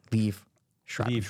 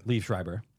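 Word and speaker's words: Liev Schreiber: You never say his name. You got to say his name. Leave I know Schreiber. He's Jewish. But you got to say his name. – Leif Schreiber.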